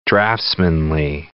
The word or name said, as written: draftsmanly